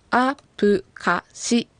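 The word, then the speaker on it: アㇷ゚カㇱ: はい、はい、そしてこのアプカシ、カタカナで書くとアプカシですが、プとシは小さい発音ですので気をつけて発音してください。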